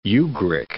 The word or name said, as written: Ugric